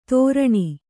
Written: ತೋರಣಿ

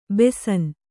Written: ಬೇಸಿನ್

